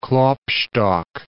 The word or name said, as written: Klopstock